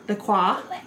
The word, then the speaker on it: LaCroix: So they had, like, this whole, like, layout of risotto and, like, chicken and, like, LaCroix. – Mm. – Or I never know how to say it. – LaCroix.